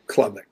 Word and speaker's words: Klubnik: Klubnik. Okay, sorry, Craig. Klubnik. I appreciate the correction.